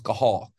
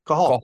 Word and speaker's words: Cathal: Yeah, it's actually pronounced Cahal. – Cahal. Cahal Roberts. Yeah, it's almost like you got something stuck in your throat.